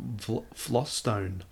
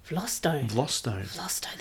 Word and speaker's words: Vlastuin: Apparently it's pronounced Vlostone. – Vlostone, that's even better. – Yeah, so it looks nothing like it.